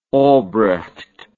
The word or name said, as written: obrecht